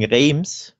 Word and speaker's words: Reims: Gab zum Beispiel eine 0-1-Niederlage in Rennes und ein 1-1, ein enttäuschendes gegen Reims. Das S, glaube ich, spricht man nicht aus.